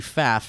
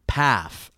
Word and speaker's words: Pfaff: My name is Maggie Pfaff. First F is silent, so it's like Pfaff.